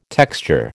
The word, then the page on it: texture: American English